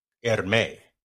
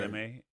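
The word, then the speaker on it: Hermès: Outside of and apparently the H is silent. Hermes. See, I rolled the R's there. – Hermes.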